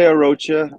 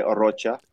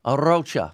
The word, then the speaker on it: Arocha: Most people say Arocha. Uh my family goes by Orocha. Orocha.